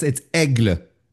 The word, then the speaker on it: Aigle: Ajay Blanc. I'm totally screwing that. It's the A-I-G-L-E. – It's Aigle. – Aigle. Aigle Blanc.